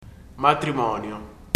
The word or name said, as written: matrimonio